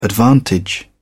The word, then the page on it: advantage: British English